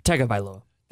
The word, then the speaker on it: Tagovailoa: Pronounce it for me. – Tagovailoa.